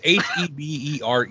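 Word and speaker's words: Hebereke: Amazing Hebricki? – Hebricki? I don't know. – It's Hebrecki. Hebrecki.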